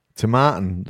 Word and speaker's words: Tomatin: So how do you pronounce that? Tomatin, there you go.